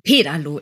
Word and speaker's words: Pedalo: Pedalo, immer, meistens auf der ersten Silbe.